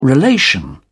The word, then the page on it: relation: British English